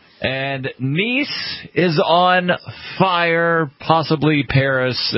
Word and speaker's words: Nice: It's nice. It's nice in southern France. – A very popular tourist destination this time of year, too. – Yeah, it's spelled nice, but it's pronounced Nice.